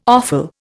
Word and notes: offal: Indian

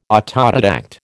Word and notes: autodidact: American English

